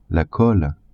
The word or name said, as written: colle